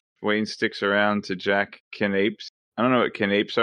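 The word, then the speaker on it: canapés: And pretty sure they're pronounced canapes. – You are correct.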